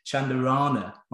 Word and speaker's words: Chandarana: I'm so bad for not doing this, but I'm delighted to welcome Millie Chandarana onto the podcast today.